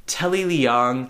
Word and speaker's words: Telly Leung: Telly Leung, did I say his name correctly? – You did, yeah.